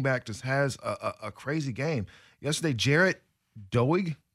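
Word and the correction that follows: Doege: I'm not even sure if I'm saying his name right. – I think it's Daigie.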